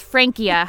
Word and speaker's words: Francia: It's Francia, hard C.